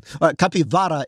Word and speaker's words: capivara: Well, I'm trying to be a little more Latin American, given that they mostly live in capivara in Brazil.